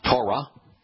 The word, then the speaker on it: Torah: All you have to do is properly pronounce the book in which he introduces himself, Torah. T-O-W-R-A-H. The O sound is from the wa, the W.